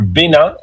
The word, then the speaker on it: Benin: But first, today we're headed to Benin, or Benin, as it's pronounced in French, a country in West Africa with deep history and powerful connection to the Black diaspora.